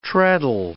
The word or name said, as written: treadle